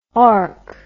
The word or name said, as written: orch